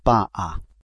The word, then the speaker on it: va a: Usted va a. It's actually two separate words, va and then a, but it sounds a little like a long A.